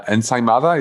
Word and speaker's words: ensaimada: and we had ensaimada, is that how you say it? – Yeah, but it was with sobrasada.